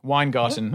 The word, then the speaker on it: Weingarten: Weingarten.